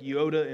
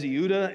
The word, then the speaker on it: Euodia: Because that looks like Yoda and Sintike to me. But uh, it is Euodia and Syntyche. That's Greek.